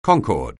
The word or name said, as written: concord